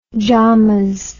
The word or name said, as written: jamas